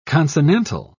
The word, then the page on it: consonantal: British English